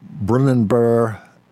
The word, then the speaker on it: Brunanburh: Brunanburh and the Birth of England.